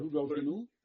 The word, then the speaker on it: Rudolfinum: Rudolfinum, pronounce? – Rudolfinum, very good.